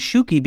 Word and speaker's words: Shuki: And I would spell it S-H-U-K-I. And they go, S-H-U-K-I, Shuki. Okay, like a shoe with a key.